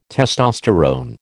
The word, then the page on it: testosterone: American English